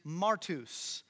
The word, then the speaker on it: martus: And the one on the bottom is martus. So a little P-looking letter is actually pronounced like an R. So martus is the Greek word for witness.